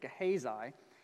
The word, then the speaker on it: Gehazi: Next, we can look at Elisha's servant, Gehazi, which, by the way, I had to listen to have an even idea how to say it. Gehazi.